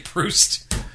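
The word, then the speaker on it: proost: It's actually Proust.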